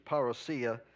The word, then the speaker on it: parousia: That's not even the right way to say it, but it's parousia, but, you know. We say parousia.